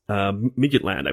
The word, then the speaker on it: Midtjylland: Manchester United going up against Denmark's Moneyball team, Midtjylland, I believe is how it's pronounced.